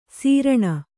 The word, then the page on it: ಸೀರ್ಣ: sīrṇa